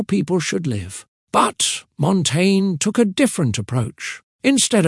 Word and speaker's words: Montaigne: Michel de Montaigne, pronounced Montaigne, was